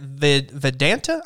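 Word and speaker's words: Vidanta: This is a Mexico Open at Vedanta, I believe is how you pronounce it. – Yep.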